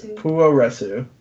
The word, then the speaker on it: Puroresu: or Puroresu, is that how you pronounce it? – Puroresu.